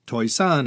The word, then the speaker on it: Toisan: A couple inaccuracies were kindly and generously pointed out to me by some of my listeners, one of which concerned my pronunciation of the word Toisan. Toisan is my poorly pronounced Cantonese of the Mandarin Taishan.